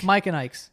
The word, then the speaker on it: Mike and Ike: Mike and Dykes. Mike and Ikes. But that's how I say it. – You say Mike and Dykes.